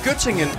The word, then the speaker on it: Göttingen: Var det ved Gødringen? – Nej, Göttingen. – Göttingen, ja, men det er... – Göttingen eller ost. – Jamen, det er bare mig, der ikke kan udtale det korrekt.